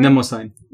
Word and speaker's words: Mnemosyne: Themis, Thea, and Mnemosyne. Do you know how to pronounce that word? – Mnemosyne.